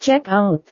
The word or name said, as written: check-out